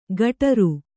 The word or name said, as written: ಕಟರು